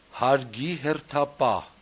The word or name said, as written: hargihertapah